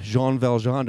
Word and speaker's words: Jean Valjean: there is a man named Jean Valjean.